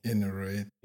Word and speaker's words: inert: Jetzt ist die Frage, wie spricht man das richtig aus? – Inert. – Inert.